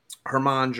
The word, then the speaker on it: Germán: But obviously, the biggest news that happened yesterday is Domingo Germán, Herman. Probably. – It's a silent G, or a light G.